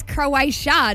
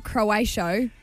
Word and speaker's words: Croatia: All right, it's Croatia, not Croatio.